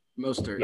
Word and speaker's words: Mostert: So their running back, I can never pronounce his name right. – Raheem Mostert. – Mostert, right? – Mostert, yeah.